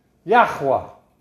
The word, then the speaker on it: Yahweh: Yahweh. That's how you say it, the Tetragrammaton in Hebrew. I am Yahweh. I am Yahweh. Y-H-W-H.